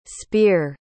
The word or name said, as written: spier